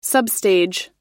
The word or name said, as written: substage